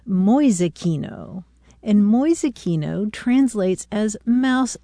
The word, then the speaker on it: Mäusekino: M-A-U-S-E-K-I-N-O, Mäusekino.